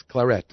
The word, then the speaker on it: claret: No, it's Claret. It's actually, you have to pronounce the T. – Claret. – Even the French and the English pronounce it with the T.